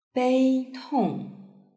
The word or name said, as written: beitong